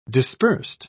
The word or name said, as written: dispersed